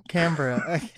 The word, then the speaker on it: Canberra: It's Canberra, whatever, wherever he's from, like where Canberra.